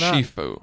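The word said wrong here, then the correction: Ciuffo: Chifo. I even Chifo. Just said it wrong. I said my own last name wrong.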